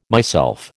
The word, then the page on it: myself: American English